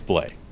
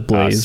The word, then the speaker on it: Blais: And I even looked it up short on Hockey References. And I think it's Blay. – I thought it was Blaze. – Sammy, Blay. Sammy's last name is spelled B-L-A-I-S. I believe. Yeah, B-L-A-I-S.